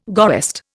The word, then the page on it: goest: Indian